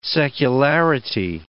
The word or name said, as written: secularity